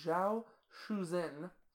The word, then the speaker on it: Zhao Shuzhen: The fourth is Zhao Shuzhen.